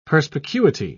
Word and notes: perspicuity: British English

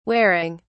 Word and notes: waring: British English

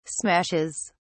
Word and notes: smashes: British English

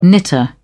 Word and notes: knitter: British English

